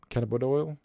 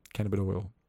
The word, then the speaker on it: cannabidiol: Cannabidiol.